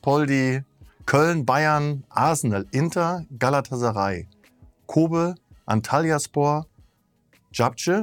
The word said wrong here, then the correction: Zabrze: da musst du mir jetzt mit der Aussprache Gurnik Sabje. – Sabje.